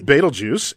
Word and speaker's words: Betelgeuse: Betelgeuse, and that's how you pronounce it, Betelgeuse.